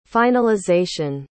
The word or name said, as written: finalization